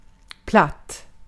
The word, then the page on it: absolutely: /æb.səˈluːt.li/; /ˌæb.səˈlut.li/; /ˈæb.səˌlut.li/